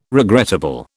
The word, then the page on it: regrettable: British English